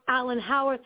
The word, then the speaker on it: Alan Howarth: And then, of course, then you had Alan Howarth, if I'm pronouncing his name right. Does that sound right to you? – Yep, that's right. – Yes, Alan Howarth.